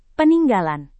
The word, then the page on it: peninggalan: Indonesian